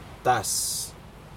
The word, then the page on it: tas: Aneityum